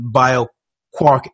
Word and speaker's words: BioQuark: BioQuark.